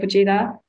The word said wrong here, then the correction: Pejeta: Oh, Pejeta. – Pejeta.